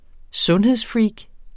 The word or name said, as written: sundhedsfreak